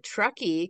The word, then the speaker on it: Truckee: She grew up in the American West, moving between Truckee, California, and Montana.